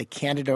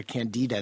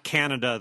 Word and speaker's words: Candida: I would highly recommend that you talk to either your family doc or your dermatologist and say you want to try the Candida or Candida. That's how some people pronounce it, injection. – Yeah, not Canada, the country. Candida.